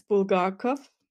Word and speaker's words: Bulgakov: So it's Bulgakov. – Okay. – Not Bulgakov or something, it's Bulgakov.